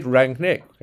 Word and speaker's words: Rangnick: So we did Rangnick. Rangnick. Ralf Rangnick. – Rangnick. Yeah. – You need your emphasis on the first part of the word. So Rangnick.